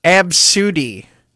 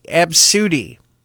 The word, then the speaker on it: Abcde: The name A B C D E, but that's how it's spelled, but it's pronounced Absudy. I think I said that right. Absudy.